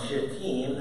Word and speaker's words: Shittim: And Joshua, the son of Nun, sent two men secretly from Shittim, that's how you pronounce that word, as spies, saying, Go, view the land, especially Jericho.